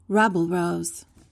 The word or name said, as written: rabble-rouse